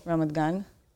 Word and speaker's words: Ramat Gan: Ramat Gan.